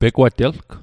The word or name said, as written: pegwatelg